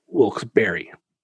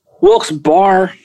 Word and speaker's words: Wilkes-Barre: I mean, to me, the word bar is spelled B-A-R. The spelling of Wilkes Berry or Wilkes Bear is B-A-R-R-E. So, I mean, if it was spelled Wilkes B-A-R, I could say, sure, Nick, Wilkes Bar makes sense.